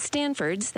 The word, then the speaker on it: Stanford's: At Stanford's, that's pronounced with a T, we will make every step as easy as possible.